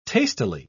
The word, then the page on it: tastily: British English